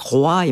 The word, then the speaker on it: roi: À l'époque, on disait le roué, c'est moué, c'est Louis XIV, et cette prononciation en oué était mieux vue, et roi et moi, qui sont les prononciations actuelles, n'étaient pas forcément bien vues, mais elles ont fini par l'emporter.